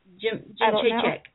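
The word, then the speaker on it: Jędrzejczyk: June 21st, we have Joan Jedrzejczyk versus pronounce it for me. – Jim Chaycheck. – Yeah. I've always said Jedrzejczyk, now it's a habit.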